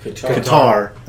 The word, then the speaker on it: Qatar: What they're doing is they're introducing Disney Plus into the following countries: Algeria, Egypt, Libya, Morocco, Oman, Palestinian Authority, the Qatar, what my pronunciation? – Qatar, thank you.